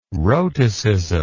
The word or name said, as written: rhotacism